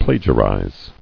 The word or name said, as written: Plagiarized